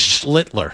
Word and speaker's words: Schlittler: It's Schlittler.